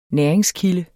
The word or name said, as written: næringskilde